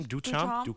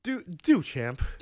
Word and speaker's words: Duchamp: And I mentioned the term anti-art, which was actually coined by Marcel how do you say his name? – Duchamp. – Something like that. Duchamp.